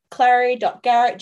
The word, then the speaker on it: clary.garrett: So on Instagram, I am clary.garrett, G-A-R-R-E-T-T.